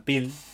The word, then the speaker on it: Peele: Em, Jordan Pelé Jordan Peele, tiene que decirlo, no lo digo bien,